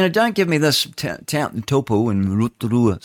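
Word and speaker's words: Taupō: They don't say taupo like we all do, you know. It's like tupu.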